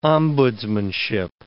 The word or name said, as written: ombudsmanship